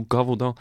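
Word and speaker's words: Gévaudan: A beast of Godovon. – I love that. Like, I love the idea. – Beast of Godovon. – Beast of Gavodon. – I'm saying it wrong. Beast of Gavodon.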